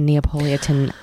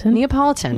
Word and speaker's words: Neapolitan: And then I started in the fourth and final Elena Ferrante Neapolitan novel. I said that wrong. – Neapolitan.